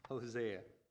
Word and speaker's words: Hosea: Hosea.